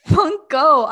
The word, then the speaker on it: Funko: Who's your favorite Marvel character, and do you have the Funkle? Is that the right word? – Funko.